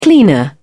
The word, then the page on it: cleaner: British English